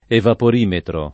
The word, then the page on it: evaporimetro: italiana